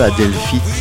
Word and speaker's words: Adelphi: Adelphi Theatre, si on prononce en anglais.